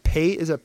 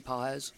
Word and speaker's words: Pages: Is it Pages or Pahe? How do you say his last name? – Andy Pahes.